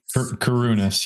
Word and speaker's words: Karunas: Like 100%. Karunas. Yep. It's Greek. All right. It's Greek. Yep. But you didn't muck it up.